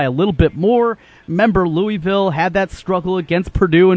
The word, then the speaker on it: Louisville: Louisville. Yeah, you got to kind of slur it around. Louisville. – Just act like you have marbles in your mouth. That's how you say it.